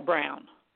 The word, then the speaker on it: Braun: Christian Brown, and B-R-A-U-N, but they pronounce it Brown.